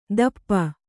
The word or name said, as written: ದಪ್ಪ